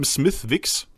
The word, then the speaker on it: Smithwick's: Geschrieben Smithwick's, aber ausgesprochen Smithwick's.